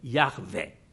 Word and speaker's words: Yahweh: 他有一个发音据说是比较最初的这个发音，这个最初的发音呢就是呢就是 Yahweh，就是 Yahweh，要希伯来文那个发音就 Yahweh，然后我们把它英文就是亚威，然后中文呢就是亚威。